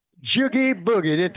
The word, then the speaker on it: jiggy boogie: So we have the wherewithal and the knowledge to do this, but we have to get, as you say, the jiggity boogity out of the way. I hope I said it right. – No, jiggy boogie. Take it like jiggy boogie.